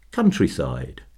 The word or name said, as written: countryside